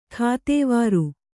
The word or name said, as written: ಕೆತ್ವಾರಿ